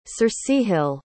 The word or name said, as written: circehyll